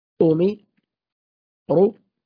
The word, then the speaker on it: أمروا: الألف بعد واو الجماعة، لو أخذنا مثال مثلا كلمة أمروا، هكذا تقرأ: أمي أمي رو أميرو رو أميرو، والألف التي بعد الواو لا أثر لها في النطق.